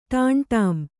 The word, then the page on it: ಟಾಂಟಾಂ: ṭāmṭām